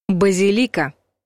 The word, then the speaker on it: базилика: Раньше вариант «базилика» был единственно верным, но сейчас в большинстве словарей дается 2 равноправных варианта — «базилика» и «базилика».